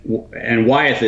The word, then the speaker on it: Wyeth: It's B-R-U-E-S-E-W-I-T-Z, Bruesewitz v. Wyeth. – Wyeth? – And Wyeth is W-Y-E-T-H, as in Wyeth Pharmaceuticals.